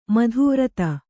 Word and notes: ಮಧುವ್ರತ: Kannada

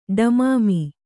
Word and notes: ಢಮಾಮಿ: ḍhamāmi

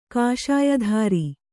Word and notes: ಕಾಷಾಯಧಾರಿ: kāṣāyadhāri

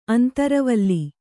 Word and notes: ಅಂತರವಲ್ಲಿ: antaravalli

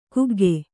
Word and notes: ಕುಗ್ಗು: kuggu

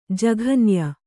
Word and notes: ಜಘನ್ಯ: jaghanya

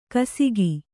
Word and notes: ಗೋಸಗೆ: kannada

